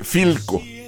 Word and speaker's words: Philco: Es Filco, que se escribe P-H-I-L-C-O, Filco. Y entonces cuando le dijeron, ah, pues esta marca, Filco, dijo, ah, así suena la PH.